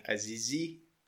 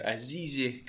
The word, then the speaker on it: Азизи: Меня зовут Самир Азизи, или же Самир Азизи, кому как больше нравится ставить ударение на мою фамилию.